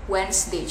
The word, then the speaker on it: Wednesday: itu biasanya kita salah tuh ngomongnya Wednesday padahal harusnya Wednesday cuma dua syllable